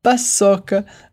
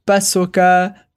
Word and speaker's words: paçoca: Ouais, mais je sais, là, j'ai ma prononciation brésilienne, parce qu'elle dit plutôt brigadeiro, paçoca, cocada, voilà, on essaye toujours de mettre l'accent sur la dernière syllabe en français.